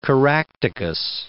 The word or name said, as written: Caractacus